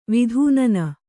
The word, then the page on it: ವಿತುನ್ನ: vitunna